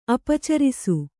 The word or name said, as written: ಅಪಚರಿಸು